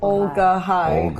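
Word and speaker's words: Olga-Hai: How do you spell that? – It's O-L-G-A hyphen H-A-I. – Thank you. – Ogulhai. Hi. – Ogulhai. – Ogul. – Olga High. – Olga. I forgot that. – Ogle. Olga High. – O-L-G-A-L.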